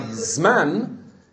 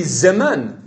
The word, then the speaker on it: zeman: Like where you have a shva at the beginning, at the beginning of the word, like zman, it should be zeman. It's not zman.